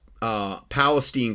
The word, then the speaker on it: Palestine: Now, I said to him, oh, and then he said, wow, you cannot even pronounce Palestine correctly, meaning East Palestine. Yeah, maybe I said Palestine, like in the Middle East.